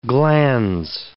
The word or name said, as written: glans